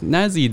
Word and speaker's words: Nasi: So today we're joined with Nasi though, or just Nasi, or am I saying that right? – Yeah, Nasi is my online handle.